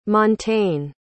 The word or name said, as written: montaigne